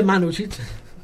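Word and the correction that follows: Mandžukić: Questo giocatore, Mario Mandzukic, ha giocato un Atletico Bilbao.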